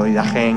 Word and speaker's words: Didache: Y bueno, pues resulta que este documento, escrito Didache, pronunciado Didache en griego, pues son 600, 700 palabras, nada más.